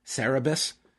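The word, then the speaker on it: Cerberus: But MJF should know that after Hercules bested the Serabes and Hydra, did I butcher that? you're asking the wrong dude, man. Cerebus, that he won and Hercules got what he wanted.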